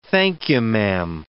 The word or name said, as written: thank-you-ma'am